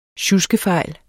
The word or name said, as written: sjuskefejl